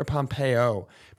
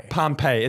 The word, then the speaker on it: Pompeii: Is it Pompeii or Pompeo? Pompeo. Ellen Pompeo. – I think it's spelled P-O-M-P-E-I-I. – Pompeii.